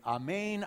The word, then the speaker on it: amen: Another that you may be less aware of is Jesus often in the Gospels will say, amen, amen. That, by the way, is how you pronounce it in Greek.